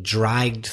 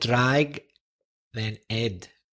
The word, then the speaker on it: dragged: You said drag, then ed, but actually it's one syllable. It's dragged. Because, as we've mentioned before, the pronunciation of ed is usually with a silent e in English, dragged, except for words ending in t and d. So dragged, one syllable.